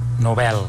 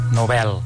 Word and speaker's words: Nobel: Nobel. Nobel. – Molt bé. – Nobel. – Ara que tenim clar que és Nobel i no pas Nobel, centrem-nos en la paraula premi, que ve del llatí praemium, prae, abans, i emere, agafar, adquirir.